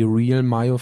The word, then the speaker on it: Real Mayo: Die wird genauso ausgesprochen. Die Real Mayo von Hellmann, also vorne Englisch, hinten Deutsch, ist die Nummer 1 Mayo aus den USA.